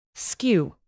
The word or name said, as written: skew